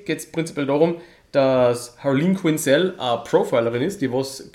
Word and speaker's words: Harleen Quinzel: Genau, Harleen Quinzel, glaube ich, spricht man es aus. – Oder? Richtig, genau.